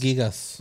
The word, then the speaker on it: Gigas: Pronto, eh, bueno, ¿cómo, se, ¿cómo se pronunciará? ¿Gigas o Gigas? como, ah, los según gigabytes. es Gigas.